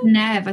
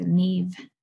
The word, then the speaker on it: Neve: But she says Neve. I think that's slightly different to Neve. This is Neve. – It's all like you pronounce it N-E-E-V, so Neve.